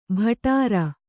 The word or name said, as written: ಭಟಾರ